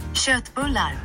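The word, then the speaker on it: Köttbullar: Schürtbullar.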